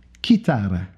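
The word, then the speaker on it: Kitara (κιθάρα): Mais euh, le mot latin, lui, il vient du mot euh, grec Kitara. Écoutez bien que c'est avec un C qui prononce, un K, je veux dire, qui prononce leur C.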